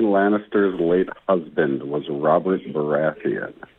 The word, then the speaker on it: Baratheon: Baratheon. – Baratheon.